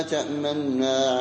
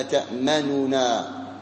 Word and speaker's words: تأمنا: تقرأ بصيغتين، إما بالإشمام، والمقصود بالإشمام أن تنطق بالحرف ساكنا وتأتي بحركة الضمة، أصل الكلمة تأمننا، فلا تقرأ النون مضمومة نو، وإنما تسكنها، تسكنها وتظهر حركة الضمة وهي ضم الشفتين، فتقرأ قالوا يا أبانا ما لك لا تأمنا على يوسف، ما لك لا تأمنا على يوسف، وبعضهم يجعل فيه صوتا أي غنة، ما لك لا تأمنا على يوسف.